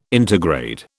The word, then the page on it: intergrade: British English